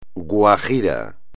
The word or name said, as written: guajiro